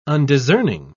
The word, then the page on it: undiscerning: British English